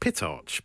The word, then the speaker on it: Pitarch: Pitarch? I presume I'm pronouncing that. – P-I-T-A-R-C-H. Pitarch.